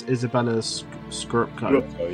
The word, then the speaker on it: Izabella Scorupco: It's Izabella Scorupco.